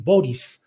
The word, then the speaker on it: Boric: En el escenario internacional, le digo que pues ha tomado protesta como presidente de Chile, Gabriel Boric, así lo pronuncian, se escribe Boric, con C al final,